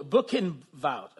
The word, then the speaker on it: Buchenwald: Buchenwald. Oh, Buchenwald.